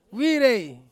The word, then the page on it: wire: Ansus